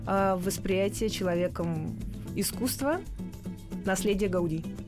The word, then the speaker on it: Гауди: 165 э, лет Антони Гауди. Наконец-то мы правильно ставим фамилию, в фамилии ударения.